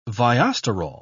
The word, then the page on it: viosterol: British English